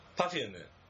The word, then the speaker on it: パフューム: なんだっけ、うん、パフューム。